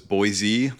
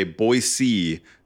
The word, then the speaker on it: Boise: Like I remember seeing it get announced and it's in Boise, Idaho, which I always thought was Boise, like pronounced like a Z at the end, but locals say Boise. So don't say Boise or it sounds like you're from out of town.